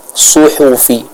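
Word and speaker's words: suhufi (صحف): Suhufi Ibrahim wa Musa, suhufi, bukan suhufi, bukan su, tapi su tebal, suhufi, seperti itu.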